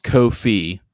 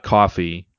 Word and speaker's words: Ko-fi: We have I did find out it is pronounced Ko-fi, not coffee, because I was wondering about that for a long time.